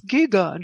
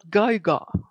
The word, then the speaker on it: Giger: eso, me recuerda al creador de Alien, porque había un audio que decía, era un niño que decía, It's Giga, not Gaiga. no sé si os acordáis de eso, porque claro, es Giger, Geiger.